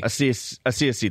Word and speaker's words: Asiasi: Five, Devin Asiasi. How do you? I don't even know how to say his name. – Asiasi. – That's what I thought.